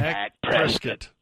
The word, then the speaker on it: Dak Prescott: Dak Prescott.